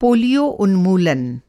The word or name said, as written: पोलियो